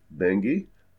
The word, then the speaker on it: Benge: Bengi, I'm going to spell that B-E-N-G-E, Bengi, George R.